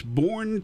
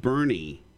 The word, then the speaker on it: Burney: it's Bourne, Texas." Dad said, "No, it's pronounced Burney."